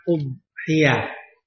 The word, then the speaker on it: udhiyah: Kalau kita sering lebih sering mengucapkan kata kurban, nah kita latih diri kita untuk mengucapkan umhiyah. Sekaligus melatih makhraj huruf bad, ya. Huruf yang paling sulit untuk diucapkan, umhiyah. Tidak boleh ada kol-kolahnya ya, karena kadang-kadang orang menyebutnya umhiyah, itu salah.